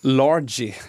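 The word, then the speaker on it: Largie: Ja, de har ju bland annat han Largi, uttalar man namnet så.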